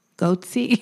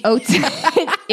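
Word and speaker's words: Ötzi: Goatsy? – Otzi. It's not Goatsy. It's spelled O with an umlaut. T-Z-I.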